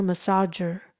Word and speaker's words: massager: Massager，massager 就是按摩器，三个音节，massager，这里的 G 是要发成 J 的声音哦。